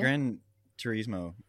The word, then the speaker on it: Gran Turismo: Yeah, Gran Turismo, is that how you say it? – Mm-hmm.